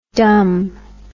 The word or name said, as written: dum